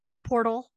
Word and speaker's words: portal: So shall we jump through a time porthole and go to 1982 Broxton, Oklahoma? – Did you say porthole? – Portal.